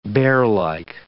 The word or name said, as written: bearlike